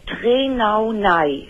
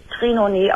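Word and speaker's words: Trenaunay: Und dann Trenaunei, hinten mit Y. Dann Syndrom, das wird Trenaunei ausgesprochen.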